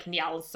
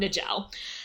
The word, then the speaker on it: Njal: So that there's one saga in particular, I apologize, my Norse is bad, but something like Njal's saga. – Oh, Sarah, we all know it's pronounced Njal. – Spelled N-J-A-L. – Your Norse is so terrible. – I'm pretty sure it's not Njal.